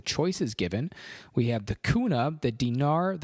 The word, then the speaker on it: kuna: So let's guess the kuna. That is what it is. Rhymes with tuna.